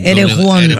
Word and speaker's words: Erewhon: Y hay unos que se llaman Erewhon. No sé si lo pronuncie como los gringos, pero bueno, Erewhon. – Se escribe Erewhon, ¿no? Chiqui Baby con... – Erewhon, ajá.